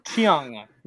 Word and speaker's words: Cheung: But just find Chris Chung, and that's Cheung. – That's right. The E is silent. – Yeah. – I'm not sure why. Chung. – Chris Chung.